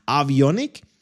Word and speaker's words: Avionik: Wird so geschrieben, wie ich es ausspreche, nur mit V, also A-V-I-O-N-I-K. Avionik. Das sind die elektronischen Geräte, die dafür sorgen, dass Flugzeuge sicher fliegen.